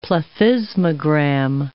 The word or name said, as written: plethysmogram